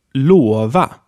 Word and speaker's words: lova: We have a long closed O here. Son, which means son. Son. And then lova, which means to promise. Lova.